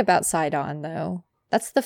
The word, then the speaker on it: Sidon: Is it Sidon? Is that how you say his name? – Yeah.